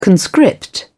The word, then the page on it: conscript: British English